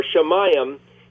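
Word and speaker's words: Shemayim (שָׁמַיִם): And actually, if you pluralize that, it's the word for the heavens. So Shem, Mim, or Shemayim is the word for heavens.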